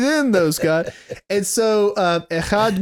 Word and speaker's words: echad: But the word for one here in Hebrew, and when I pronounce it's going to sound like I'm hawking a loogie because Hebrew generally sounds like that, but echad.